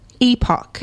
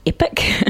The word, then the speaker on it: epoch: Epoch. – Mm. I think, yeah, the American pronunciation is epic, which you may have heard people say in TV shows or whatnot, but epoch is the British pronunciation. – So E-P-O-C-H? – Mm-hmm, that's okay.